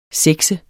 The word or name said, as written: sexe